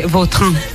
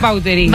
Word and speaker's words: Vautrin: Vautrin. Pero aquí decimos Vautrin. – Claro, sí, ¿no? – Es que si me pregunta exactamente cómo se pronuncia, pues así, claro, pero sí. Yo ya soy Vautrin. – Ya. – Está españolizado total.